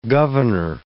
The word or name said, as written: governor